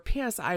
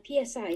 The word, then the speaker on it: Psi: For those who don't know exactly what parapsychology or PSI research is, can you explain that a little bit? – Yeah, sure. PSI, generally known as Psi or Psi, depending on how you like to pronounce it, is kind of the academic term we use to talk about all things psychic.